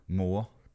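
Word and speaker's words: mor: So G-A-D-E. – Just tell how they pronounce the word mother or mom. – Moor.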